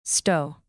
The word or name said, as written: stow